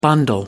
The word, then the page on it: bundle up: British English